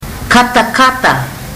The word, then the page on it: katakata: fiji